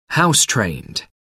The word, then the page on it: house-trained: American English